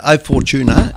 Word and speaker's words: O Fortuna: The first song is, I hope I'm saying it right, Camina Burana O Fortuna. Is that right? – Yeah, O Fortuna is how most people know it, yeah.